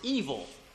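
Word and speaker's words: evil (אויל): Um, it's funny, the Hebrew word for this is pronounced evil.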